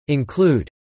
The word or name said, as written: include